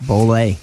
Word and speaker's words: Bolay: Yep, Bolle.